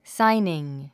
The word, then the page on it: signing: sáiniŋ; サァイニン